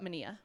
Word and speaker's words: Manilla: It's like vanilla, but Manilla.